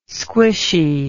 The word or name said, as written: squishy